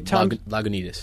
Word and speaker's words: Lagunitas: Nick says Lagunitas Undercover Ale. Mm-hmm. And I hope I'm pronouncing that one correctly. – Lagunitas.